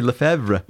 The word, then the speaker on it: Lefebvre: Well, how do you pronounce it? – I say Lefebvre. – Well, that's right.